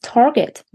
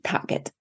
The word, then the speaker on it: Target: Mami, das heißt Target, nicht Target.